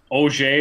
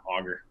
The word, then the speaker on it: Auger: Auger is actually the French pronunciation. So growing up, I heard a lot of people, especially refs from, you know, Montreal area and stuff, saying Auger, but yeah, Auger. Auger's right.